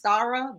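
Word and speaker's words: Starra: It, it's pronounced Stara, but like if someone from my hometown is named Starwell. We just say Star.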